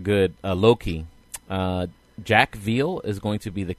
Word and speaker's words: Veal: Veal, like I.E. Veal, you know, V-E-A-L.